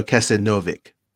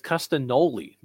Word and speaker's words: Castagnoli: Claudio Castagnovic. Say it correctly. – No, it's Castagnoli, not Novik.